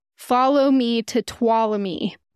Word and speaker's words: Tuolumne: Because I always said Tuolumne. – Tuolumne Meadows. – Because that's how it's spelled. That's what it looks like. But apparently it's Tuolumne. You don't even say the N.